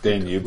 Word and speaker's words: Danube: Danube flotilla. – Like the river.